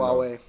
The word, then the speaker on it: Huawei: auf Deutsch ausgesprochen Huawei. Irgendwie so ein Abhörskandal gab. – Aber man spricht es wirklich so aus in deren Sprache, ja, genau. Huawei.